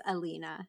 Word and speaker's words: Elina: It is Elina.